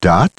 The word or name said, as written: Dot